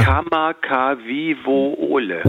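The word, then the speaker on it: Kamakawiwole (Kamakawiwoʻole): Wie spricht man den aus? Kamakawiwiwow? – Ulle? Würdest du mich jetzt so... Ich habe es jetzt gerade auch lustigerweise mir ja nochmal vorgeholt. Ja. Kamakawiwole. – Ole, genau so.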